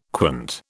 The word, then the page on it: quant: British English